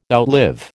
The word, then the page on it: outlive: American English